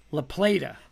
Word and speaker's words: La Plata: La Plata.